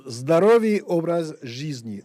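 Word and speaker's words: Zdorový obraz jízdní (Здоровый образ жизни): Zdovoré obraz jízdní, ou littéralement mode de vie sain. Hein, c'est ça, je vous laisse le dire avec la bonne prononciation qui va. – Zdorový obraz jízdní, ZOJ.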